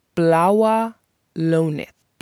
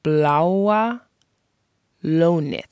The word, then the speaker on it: Bláa Lónið: The Icelandic word of the episode is dedicated to the Blue Lagoon, which in Icelandic is pronounced Bláa Lónið. Bláa Lónið. Bláa Lónið.